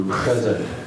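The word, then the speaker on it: chesed (חסד): That's how it's pronounced in Hebrew, chesed.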